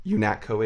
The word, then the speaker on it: UNATCO: He's like a nano augmented UNATCO agent, I believe it is, United Nations Anti-Terrorist Coalition. – That's right, UNATCO. You pronounced that right.